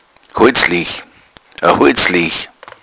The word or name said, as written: holzlege